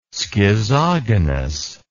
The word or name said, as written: schizogonous